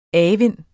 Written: avind

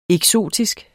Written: eksotisk

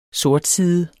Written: sortside

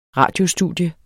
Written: radiostudie

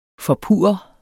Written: FORPURRER

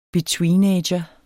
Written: betweenager

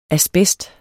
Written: asbest

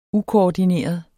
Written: ukoordineret

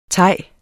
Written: thai

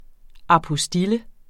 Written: apostille

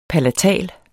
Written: palatal

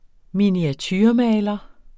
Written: miniaturemaler

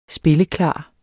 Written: spilleklar

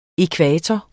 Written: ækvator

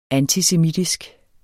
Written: antisemitisk

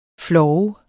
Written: flov

